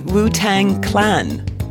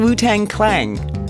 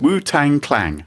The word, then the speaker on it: Wu-Tang Clan: You can't say the word, can you? Wu-Tang Clan. – Yes, I'm saying that correctly. – It sounds like you're saying Wu-Tang Clang. – Oh. – Yeah. – Oh, no, but it has a G on the end. – No, it doesn't.